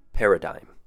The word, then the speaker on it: paradigm: First word, paradigm. Three syllables. Pe has a short E sound, R has a short U sound, and dime, as in the coin, dime. Paradigm.